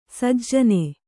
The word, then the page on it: ಸುಜನ: sujana